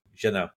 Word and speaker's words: Jeunet: Don't pronounce the T. – So the summary is... – Yeah, we can have this. You don't pronounce T's in the French language, so Jeunet.